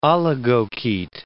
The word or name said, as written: oligochaete